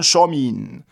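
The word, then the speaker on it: Samhain: Et ce changement d'année qui porte le nom, alors vous prononcez comme vous pouvez, il faut prononcer normalement Shomin, en gaélique d'Irlande actuelle, ça s'écrit Shamain, S-E-H-A-M-E-A, accent grave, I-N.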